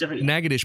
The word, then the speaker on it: Natchitoches: Oh, so it's pronounced Natchitoches, but it's spelled Natchitoches.